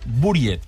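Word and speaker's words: Buried: Buriet, perquè s'escriu així, d'aquesta manera, amb la U. Però en realitat exactament, es podria pronunciar d'aquesta manera com acabes de dir tu, Edi.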